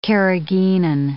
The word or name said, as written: carrageenin